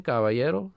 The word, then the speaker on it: Caballero: And then my last name, Caballero, C-A-B as in boy, A-L-L-E-R-O, show, S-H-O-W.